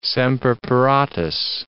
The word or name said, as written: semper paratus